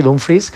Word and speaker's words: Dumfries: Yo creo que la llegada de Dumfries, que además dice que creo que ha pedido que le llamemos así, Dumfries, casi como se escribe prácticamente,